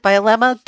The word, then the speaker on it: Bielema: Brett Bielema, I believe that's how you pronounce it, Bielema, the defensive line coach for the Patriots, is headed to the Giants, according to Ian Rappaport of the NFL Network.